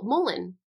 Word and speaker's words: mullein: Oh, Mullein.